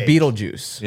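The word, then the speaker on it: Betelgeuse: Yeah, but it's pronounced like juice. – But it's pronounced Beetlejuice.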